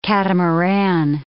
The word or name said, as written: catamaran